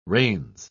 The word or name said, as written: reins